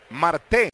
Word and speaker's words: Marte: Marte, él con acento en la última sílaba.